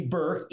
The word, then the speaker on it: Burke: You can call me AB or Anthony Burke, either one. We joke, Liz, that my last name rhymes with jerk, not dork.